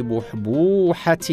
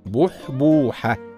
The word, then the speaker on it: بحبوحة: من الكلمات الداله على السعه والكثره في اشياء كثيره كلمه بحبوحه نقول فلان في بحبوحه من العيش هكذا تنطق بحبوحه بضم الباء ولكن البعض ينطقها بغير ذلك وهذا خطا شائع والصواب نطقها هكذا بحبوحه